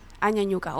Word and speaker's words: Añañuca: Bueno, el cómo nació igual nos contaste un poco, pero de qué se trata la ruta de las Añuñucas, ¿cierto? – Añuñucas. Sí. – Añañuca, oye, lo digo, soy pésima pronunciando cosas que no conozco. – Con la ñ. – Con la ñ, claro.